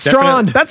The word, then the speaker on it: Strachan: It's the way that I remember it is it's like how the word draft is spelled in Europe, like draught. – Yeah. – That's how I think of Strawn.